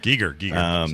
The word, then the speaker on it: Giger: Is it Giger, Geiger? I never say it right. Whatever. – Giger. Sorry.